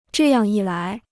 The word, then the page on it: 这样一来: zhè yàng yī lái